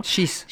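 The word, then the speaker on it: Schiess: Euh, par exemple, le calvaire vécu par Gabi, Gabriella Andersen Chase, si je prononce bien. Chis. Chis, pardon. 1984 à, Los Angeles.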